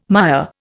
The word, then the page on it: mire: Indian